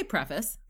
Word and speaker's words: preface: How do you say that word? – I say preface.